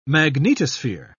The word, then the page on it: magnetosphere: British English